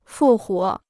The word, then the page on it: 复活: fù huó